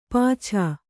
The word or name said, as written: ಪಾಛಾ